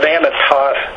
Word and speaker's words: Anathoth: Yeah, that Anathoth, the Holman's Illustrated Bible Dictionary, it says that, or I guess it's Anathoth, sometimes the H is silent.